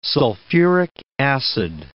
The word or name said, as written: sulphuric acid